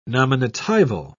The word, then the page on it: nominatival: British English